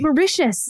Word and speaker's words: Mauritius: Oh, correctly. Yes, Mauritius. Yeah. Yes, which sounds like something negative you say about someone, like avaricious, you know, like he's deeply Mauritius. – Yeah. – Like it's a real negative attribute of someone. – Well, we were. – But it is also the correct pronunciation of the country.